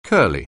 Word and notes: curly: anglais

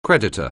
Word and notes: creditor: anglais

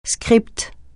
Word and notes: scripte: francais

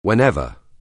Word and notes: whenever: anglais

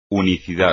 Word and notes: unicidad: espagnol

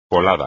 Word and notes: colada: espagnol

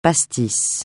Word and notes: pastis: francais

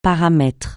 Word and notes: paramètre: francais

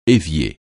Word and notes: évier: français

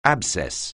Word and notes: abscess: anglais

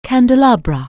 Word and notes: candelabra: anglais

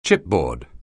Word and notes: chipboard: anglais

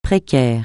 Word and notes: précaire: francais

